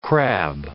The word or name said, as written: crab